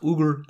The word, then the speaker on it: Uyghur: If most people, they pronounce it Uyghur. It's actually pronounced Uyghur, according to one of my Russian friends.